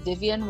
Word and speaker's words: Vivienne: So on Instagram I am Vivian Wong Sing Along, VIVIENNE. So Vivian Wong Sing Along.